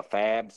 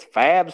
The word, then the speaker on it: FAAB: You think it's fab spending or fob, like a sob? – I thought it was like the pivot where it was like F-A-A-B. Isn't that how you say it? Just like that? – Say F-A-A-B every time?